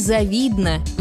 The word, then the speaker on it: завидно: Согласно орфоэпической норме, ударение падает на второй слог. Ей завидно, ему завидно, нам завидно, отчего многие произносят неправильно.